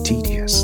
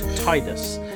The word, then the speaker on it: Tidus: I mean, in Kingdom Hearts, I've heard that it's pronounced tedious because apparently the Japanese pronounce the I's as E rather than I. So when I was originally playing, it was Tidus, which made sense because of the water theme.